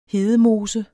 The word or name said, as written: hedemose